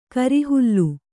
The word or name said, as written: ಕೋರೆಹಲ್ಲು